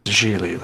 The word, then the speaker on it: Gigli: From, of course, his magnum ninth circle of the Inferno opus, the film Giggly. – Gigli, like, rhymes with really.